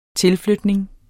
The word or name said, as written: tilflytning